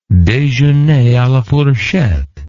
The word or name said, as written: dejeuner a la fourchette